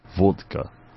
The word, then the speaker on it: vodka: Graham wants you to please say the word V-O-D-K-A. – Vodka. – Vodka. – What's wrong? – No, you say it like the Russians.